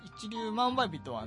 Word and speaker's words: 一粒万倍日: そしてですね、まあ、そんなこんなで始まった文化の日なんですが、今年の 11 月 3 日は結構特に、例年になくすごい日でですね、なんと新月と一粒万倍日という吉日が重なってるんですね。 11 月 3 日には、一粒万倍日とは何かというと、一粒の 1万倍の日って書くんですけど、一粒万倍日と書いて一粒万倍日って読むんですけど、このね一粒種をまいたら 1 万倍になるという意味合いの日でですね、宝くじ売り場なんかにもですね、本日一粒万倍日とかよく書かれています。